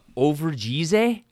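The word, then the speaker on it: Overijse: There was a cyclocross race, a UCI cyclocross race in Overgize. I'm sure I butchered that. It's somewhere nailed it.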